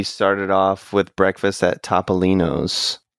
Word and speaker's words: Topolino's: So I googled it, and the appropriate Italian way to pronounce it is Topolino's.